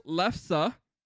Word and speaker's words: lefse: So it's pronounced lefse.